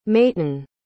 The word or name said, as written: mayten